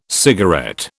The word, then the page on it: cigarette: British English